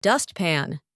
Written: dustpan

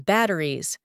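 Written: batteries

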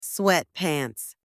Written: sweatpants

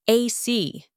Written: AC

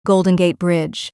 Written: Golden Gate Bridge

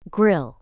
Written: grill

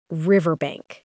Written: riverbank

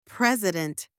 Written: President

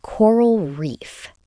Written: coral reef

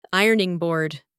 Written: ironing board

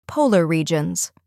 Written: polar regions

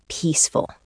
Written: peaceful

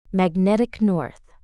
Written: Magnetic North

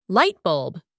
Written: light bulb

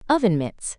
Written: oven mitts

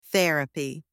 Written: therapy

